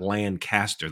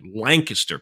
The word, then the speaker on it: Lancaster: No, I'm saying it right. It's not Lancaster. That's in California. Lancaster, Pennsylvania, although spelled the same.